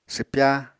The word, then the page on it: swpya: apatani